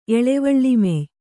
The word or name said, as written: ಎಳವಳ್ಳಿಮೆಯ್